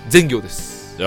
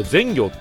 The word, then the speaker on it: 善行: 藤沢の全行です。 – いや、いいとこよね。 – 全行団地。 – あれ善行って読むんですか？ – はい。 – あ、義行だと思ってました。